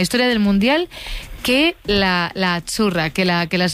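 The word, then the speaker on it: Azzurra: Azurra. Sí, como porque pizza. La Z no es la Z como decimos en catalán tan sonora, no. Ah, es azurra. – Muy bonito, muy bonito. Sí, es como pizza.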